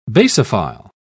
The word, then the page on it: basophil: British English